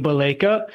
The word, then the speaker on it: Baleka: The H is silent. It's Sipiwe Baleka.